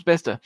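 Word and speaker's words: Bicester: Bister. Pronounced Bister.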